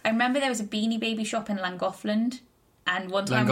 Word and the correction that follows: Llangollen: Is that not how you say it? – Llangollen. – It's Llangollen. – Llangollen. – Llangollen. Yeah. – There's no D on the end. Llangollen.